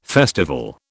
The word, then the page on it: festival: British English